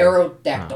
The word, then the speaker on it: Aerodactyl: Aerodactyl. Oh. Well, you would think it would be T-I-L at the end, but it's T-Y-L.